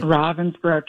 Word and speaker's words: Ravensbrück: Ravensbrück? Is that how you say it? – Ravensbrück, yeah.